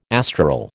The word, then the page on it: astral: American English